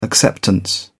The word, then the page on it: acceptance: British English